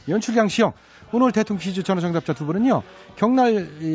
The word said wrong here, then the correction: 경남: 이, 경날은 없죠요 경남입니다. 제가 나이가 먹어가지고 발음도 또 시원찮고.